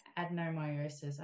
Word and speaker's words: adenomyosis: Adenomyosis.